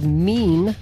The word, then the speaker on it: Mien: Anyway, we also heard some non-folk music in there, courtesy of a band called Mean. That's how they say they pronounce it, M-I-E-N, from their album from last year, spelled M-I-I-E-N, or maybe it's just self-titled, I don't know.